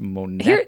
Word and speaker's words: Monet: Oh, it's actually pronounced Monet.